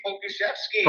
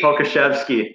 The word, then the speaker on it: Pokuševski: Alexei Pokuševski. – Pokuševski.